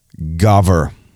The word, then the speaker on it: gaver: The men of Judah pronounced rooster in a different word, in a different way. And it's the word gaver. Gaver.